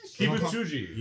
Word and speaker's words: Kibutsuji: Was it Muzan Kibut? Ah, oh, fuck! I remember what the song. Just call MJ. – Kibutsuji. – Yes. – Muzan Kibutsuji.